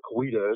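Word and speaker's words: Coweta: William McIntosh was the Creek or the Cowichaw chief of the town of Cowichaw, which are Cowita, as people pronounce it today.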